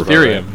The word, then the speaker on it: Ethereum: Ethereum, yeah.